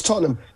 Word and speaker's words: Tottenham: It's Tottenham. You can't say it posh. You got to say it like Tottenham.